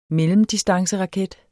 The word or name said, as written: mellemdistanceraket